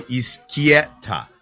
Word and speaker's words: Izquieta: I've done like my name is spelled Izquieta, right? But I've written down Iskii, like you're skiing, Ski, right? Ehtah. So Izquieta.